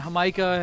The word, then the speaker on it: jamaica: It's spelled like Jamaica, though. – You had it right.